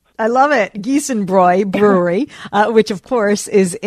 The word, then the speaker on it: Giesenbräu: It's Giesenbräu.